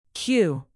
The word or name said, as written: queue